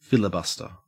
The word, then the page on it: filibuster: Australian English